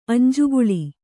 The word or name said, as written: ಅಂಜುಕುಳಿ